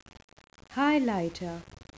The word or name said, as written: highlighter